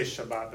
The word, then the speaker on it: Shabbat: And they would say Shabbat, that's how they pronounce it nowadays.